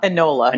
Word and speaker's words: Enola: Ilona Holmes. Ilona? No. – Enola. – Enola. I knew I was saying it wrong.